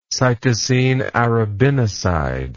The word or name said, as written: cytosine arabinoside